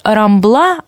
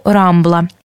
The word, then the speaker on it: рамбла: Но имейте в виду, что каталонцы обычно ударение делают на последнем слоге, как французы. Например, говорят «рамбла», а не «рамбла».